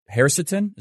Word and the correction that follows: quercetin: is that right? Quercetin? Hercetin? – Yeah, quercetin.